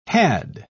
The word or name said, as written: had